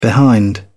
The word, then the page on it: behind: British English